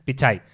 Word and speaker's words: Pichai: Sí, que nunca sé cómo se dice si es Sundar Pixai o Pixar. Para nosotros es Sundar Pixar. – Claro, entonces, bueno, en fin. – Pichai.